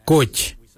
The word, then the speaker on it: goodj: Garli is spelled G-I-I-L-Y-I, garli. For the traditional stone axe, we say gouge, gouge. Gouge is spelled G-O-O-D-J, gouge.